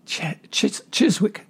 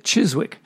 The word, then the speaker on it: Chiswick: which was their first single on Cheswick. If that's pronounced right, Chiswick. That's it, Chiswick.